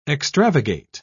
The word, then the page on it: extravagate: British English